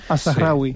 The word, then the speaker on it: Al-Zahrawi: Al-Zahrawi.